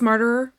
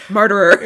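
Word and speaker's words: Marterer: Oh, is this Martyr? – Yes. – Okay. Still don't know who you are. I only remember... – Every time we say his name also, it sounds like we're saying murderer.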